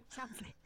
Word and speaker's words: Schaffner: Es, Schaffner, no sé si lo habré dicho bien. – Sí, suena bien. – Schaffner. – está bien. – Debe ser alemán. Sí, ¿no es cierto? Schaffner. sí, Schaffner.